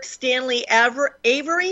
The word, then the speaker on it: Stanley Avery: So what I would like to do is welcome for the first time to Republic Broadcasting Network, Stanley Avery. Is that how we pronounce it, Stanley? – That's it.